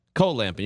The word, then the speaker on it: cold lamping: Yeah, cold lamping. You got to say it like that. You don't have to pronounce every letter.